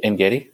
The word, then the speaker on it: En-Gedi: How do you say that? En-Gedi? – En-Gedi is good.